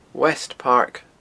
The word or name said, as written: west park